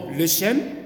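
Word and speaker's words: lechem: le chem, L-E-C-H-E-M, e hein. je le prononce comme ça la française.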